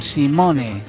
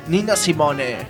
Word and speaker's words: Nina Simone: ¿Nina Simon? – No, Simone. – ¿Nina Simon? – No, Simone. – Nina Simone.